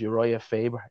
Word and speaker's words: Urijah Faber: The opposite coach was Urijah Faber. Is it, am I pronouncing that right? Yeah.